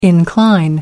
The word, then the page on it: incline: American English